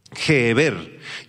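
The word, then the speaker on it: Gever (גבר): Y esta palabrita hebrea hombre es la palabra, la voy a deletrear, G-H-E-H-B-E-R, G-H-E-H-B-E-R con acento en la segunda E, Gever, Gever, que describe, escucha, esta palabra describe a un hombre fuerte o a un ser fuerte, poderoso, guerrero y con la habilidad de pelear.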